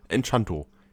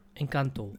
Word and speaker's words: Encanto: Und in diesem Fall, in diesem Jahr, ist das Ganze äh, äh, Spanisch. Encanto, Enchanto. Encanto. – Encanto. – Es, irgendwie Encanto. spricht man es aus.